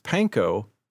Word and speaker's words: panko: The correct pronunciation of this Japanese word is panko.